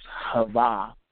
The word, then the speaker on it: Chayah: It's spelled C-H-A-Y-A-H, but pronounced Hava.